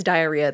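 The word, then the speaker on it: Dierya: It's spelled, okay, the way I say it is diarrhea. That's not how it's spelled. It's D-I-E-R-Y-A.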